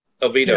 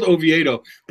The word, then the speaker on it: Oviedo: Oviedo. – Yes. – Oviedo. Yeah, no, it's spelled Oviedo, but I knew it wasn't pronounced that way.